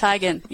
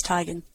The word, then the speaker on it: Teigen: Is it Teigen? – It's Teigen, yes. It was a big thing where she corrected it one day because nobody pronounced it right, but it's Teigen.